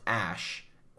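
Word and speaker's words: X Æ: No, it's XAE, which is pronounced Ash, and then something X12 or whatever.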